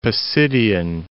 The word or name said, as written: Pisidian